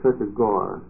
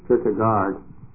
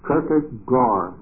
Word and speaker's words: Kierkegaard: The pronunciation is Kierkegaard. Can anybody correct me on that? That's what I've been told. I used to first pronounce it Kierkegaard in like a Dutchman would, but it isn't that. It's Kierkegaard, I'm told.